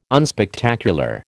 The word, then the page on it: unspectacular: American English